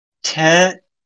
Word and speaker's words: TAN: Anyway, what it is they want you to say T-A-N. – Oh, okay. That would make more sense. Okay, then T-A-N. Fine.